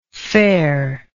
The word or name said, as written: fairness